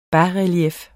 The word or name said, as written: basrelief